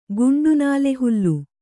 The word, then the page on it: ಗಂಡುನಾಲೆಹುಲ್ಲು: Kannada